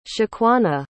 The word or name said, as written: shaquanna